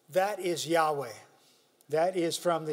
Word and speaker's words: Yahweh: They simply had the consonants, and it would equate to YHWH, and we pronounce it Yahweh. In some of the older translations of the Bible, it is referred to as Jehovah. But more correctly, it should be Yahweh.